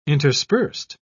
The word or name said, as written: interspersed